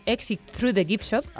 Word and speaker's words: Exit Through the Gift Shop: Te sonará porque es el prota del famoso documental Exit Through the Gift Shop.